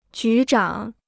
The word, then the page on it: 局长: jú zhǎng